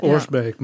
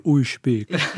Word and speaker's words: Oorsbeek: Oorsbeek. Maar je kunt het ook echt op zijn plat Limburgs doen en dan is het iets van Oeisbeek.